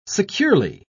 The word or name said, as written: securely